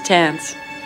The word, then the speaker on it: Tants: Tants. – Do you spell that? – T-A-N-T-S. – You know, like pants, but... Tants.